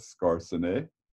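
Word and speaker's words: Skarsaune: His name was Oscar Skarsene. You have to say it like that, Skarsene.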